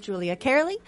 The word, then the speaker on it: Julia Cearley: Guys, find me on Twitter and Instagram with my name, which is Julia Kearley, which is spelled J-U-L-I-A-C-E-A-R-L-E-Y.